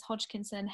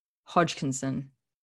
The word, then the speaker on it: Hodgkinson: C-H-L-O, and then my surname is Hodgkinson, H-O-D-G-K-I-N-S-O-N.